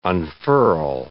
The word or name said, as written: unfurl